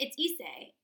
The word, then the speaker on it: Issei: So on the afternoon of June 12th in 1981, Issei Sagwa, I have no fucking clue how to pronounce his name, and I think it's Issei.